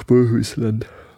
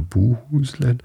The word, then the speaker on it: Bohuslän: Upland bedankte sich und nahm die Gabe an und eilte dann nach Bohusland. Äh, Bohusland, genau so heißt es. Oder Bohusland. Im Schwedischen spricht man O's manchmal wie U's aus wie auch immer.